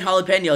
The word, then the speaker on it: jalapeño: It starts with a J, but the J is silent. It's there we go. Oh boy. I thought J and jalapeno is silent.